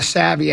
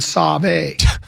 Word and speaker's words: savvy: one of the DJs from one of the local stations here did a liner for Chad Barrero, and they mispronounced savvy and said save, the save afternoon gabathon.